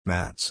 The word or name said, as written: mats